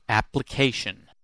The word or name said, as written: application